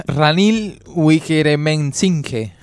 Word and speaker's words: Ranil Wickremesinghe: Ranil Wickremesinghe.